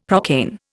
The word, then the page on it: procaine: Indian